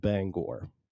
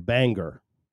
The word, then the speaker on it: Bangor: Now, notice how I just pronounced Bangor if you're not from Maine, you pronounce Bangor Banger.